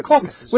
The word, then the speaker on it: Caucasus: it's the Caucasus. Is that how you say it? The mountains there? – The Caucasus.